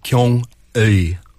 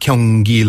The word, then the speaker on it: Gyeongui: This is called the Gyeongui line, right? As it's not Gyeonggi like Gyeonggi-do.